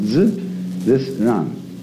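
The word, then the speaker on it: z, this run (自然): This is pronounced z, this run.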